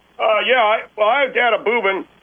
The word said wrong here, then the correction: bourbon: It's called a bourbon. – It's called bourbon, please. Please don't mispronounce it again.